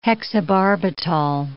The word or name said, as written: hexobarbital